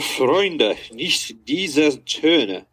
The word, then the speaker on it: Freunde, nicht diese Töne: Oh, Freunde, nicht diese Töne.